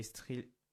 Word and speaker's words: thriller: En 6e position, on a Mobland, un drame policier et thriller. Attends, vous prononcez ça comment? – Thriller. – Ouais, ouais, tu l'as bien prononcé.